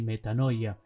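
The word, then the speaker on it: metanoia (μετάνοια): Conviértanse en realidad viene de una palabra griega que se pronuncia así, metanoia, que significa, en definitiva traducida, cambio de mentalidad, meta, cambio, noia, mente.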